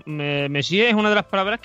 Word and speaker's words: Monsieur: como, ¿Messier? me, es Monsieur.